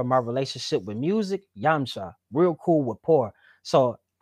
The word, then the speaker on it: Yamcha: So it's Yamcha. – Okay, so I'm so sorry. Cool, I said it the wrong way. Yumchi.